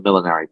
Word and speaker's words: millenary: And when James the Sixth of Scots becomes James the First of England, Ireland, and Wales, there is something called the Millenary Petition, and I'm thrilled I pronounced that right because I always trip over millenary, and I don't know why.